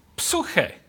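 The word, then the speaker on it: psuche (ψυχή): You know, the Bible talks about the salvation of the soul, and the word soul in Greek is the same as from which we get our word psyche, pronounced in Greek psuche.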